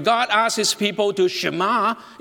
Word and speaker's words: Shema: In Hebrew, it's the word Shema. Now, it's easy to remember. In order to help you to remember the pronunciation of this word, you know, just repeat after me. Go, shh, ma, shema. Great.